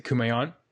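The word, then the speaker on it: Kumaon: I've read Maneaters of, I'm never sure if I say it correctly. Is it Kumayan? – Yeah, that's right.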